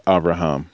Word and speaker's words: Avraham: So anyway, just a little note on that even if you see it written Avram, you still pronounce it Avraham.